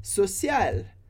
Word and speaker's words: social: E aí, no final, a última palavra é social. Social. A gente termina com um L bem alto no céu da boca, social, mas a gente não pronuncia nem o E, nem o S. Então, social.